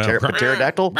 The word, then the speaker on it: pterodactyl: Pterodactyl? How do you say it? Pterodactyl? – Pterodactyl is correct. Pterodactyl. Yep.